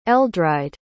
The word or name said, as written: eldride